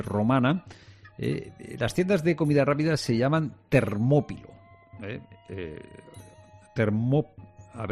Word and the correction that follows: termopolio: Termopolio, no Termópilo. Termopolio.